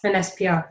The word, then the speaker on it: Phinesse: Yes, Phinesse PR, yes.